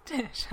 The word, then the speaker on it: Tish: My name is Tish. I was born with gills like a fish.